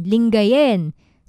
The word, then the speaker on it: Lingayen: Sa kakakaiba yung pag-pronounce nga nung E sound, ganyan. So, maswa siya. So, Bimaloy, ayan, Lingayon.